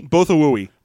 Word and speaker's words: Bothawui: Bothawui. – Bothawui. – Bothawui, because they're Bothans. – Yeah, but so, the way that it's spelled, I was like, is that Bothaway? – No, it's Bothawui. It's Bothawui.